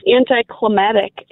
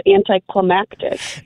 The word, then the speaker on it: anticlimactic: It's climactic. There's another C in the end of it, which is so, there's like two C's, anticlimactic. Oh, it's so annoying. – Oh, I think I say it wrong. – Everybody says it wrong, though. Everybody says it wrong. – Yeah, I say it wrong. – There's an extra syllable in there.